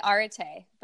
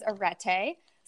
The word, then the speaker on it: Arete: I say Arete, but there are other ways to say it. My co-founder has her own way of saying it. She says Arete.